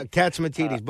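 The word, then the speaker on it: Catsimatidis: I always say his name wrong. – Yeah, Katsimatidis.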